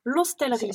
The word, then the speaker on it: L'Hostellerie: Tu prononces le S. J'ai mal prononcé tout, ah tout l'interview, mince. L'hostellerie. C'est pas grave. – L'hostellerie, mais c'est pas grave. – c'est comme dans le lot aussi, on prononce toutes les lettres. mais euh, je pensais que c'était un S muet, ben je suis désolée.